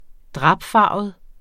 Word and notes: drapfarvet: -ˌfɑˀvəð; -ˌfɑːvəð

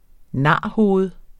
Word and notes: narhoved: ˈnɑˀ-

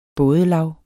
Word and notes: bådelav: -ˌlɑw; -ˌlɑwˀ

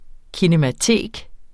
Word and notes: kinematek: kinəmaˈteˀg

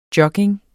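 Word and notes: jogging: Danish

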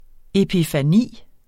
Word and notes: epifani: epifaˈniˀ

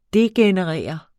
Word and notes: degenerere: [ ˈdegεnəˌʁεˀʌ ]; [ ˈdegenə- ]

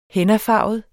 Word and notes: hennafarvet: [ -ˌfɑˀvəð ]; [ -ˌfɑːvəð ]

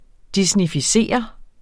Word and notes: disneyficere: disnifiˈseˀʌ

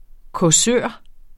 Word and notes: causør: kɔˈsøˀɐ̯; -ˈsœːɐ̯

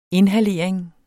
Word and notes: inhalering: [ ˈenhaˌleˀɐ̯eŋ ]; [ enhaˈleˀɐ̯eŋ ]

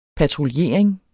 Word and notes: patruljering: patʁulˈjeˀɐ̯eŋ

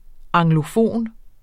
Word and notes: anglofon: [ ɑŋloˈfoˀn ]; [ ɑŋglo- ]

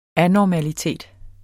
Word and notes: anormalitet: ˈanɒmaliˌteˀd

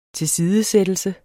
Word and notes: tilsidesættelse: teˈsiːðə-

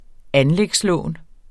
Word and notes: anlægslån: ˈanlεgs-

